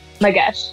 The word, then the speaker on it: Madesh: Daksha with 3 A's underscore Magesh, M-A-D-E-S-H.